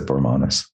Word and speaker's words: Bormanis: I said Bormanis. – That's the way I pronounce it. Thank you.